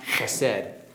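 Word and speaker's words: chesed (חסד): It's pronounced chesed.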